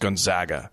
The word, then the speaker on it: Gonzaga: Because it's pronounced Gonzaga.